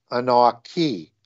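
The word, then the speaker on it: anaqi: but that would be now pronounced Anaki.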